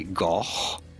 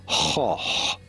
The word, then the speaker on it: Gogh: And then in Dutch they do not say goch, but rather hoch. The G is not pronounced like a G, but like a H, hoch.